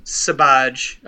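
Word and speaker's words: Sbarge: Raphael, how do you say his last name? – Sabaj. I slaughtered it for years, but I figured it out.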